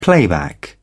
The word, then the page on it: playback: British English